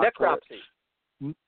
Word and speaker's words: necropsy: How do you pronounce that word? I seem to have a mental block. – Necropsy. – Necropsy.